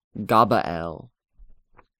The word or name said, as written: Gabael